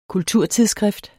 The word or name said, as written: kulturtidsskrift